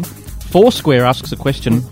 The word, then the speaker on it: Foursquare: Careful how you say that. – I've said Foursquare. I'm making a conscious effort to pronounce it correctly every time I say it, Andrew.